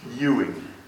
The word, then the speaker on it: Ewing: The tune name up in the middle is Ewing. That's how it's pronounced.